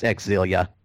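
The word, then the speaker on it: Xillia: Yeah, if you haven't played Tales of Zillia 1 and 2, they're both. Tales of Zillia is $4.99. Tales of Zillia 2 is, first of all, it's Exilia. That's how it's pronounced.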